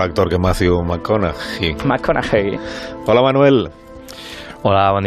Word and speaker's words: McConaughey: Es McConaughey.